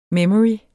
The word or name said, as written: memory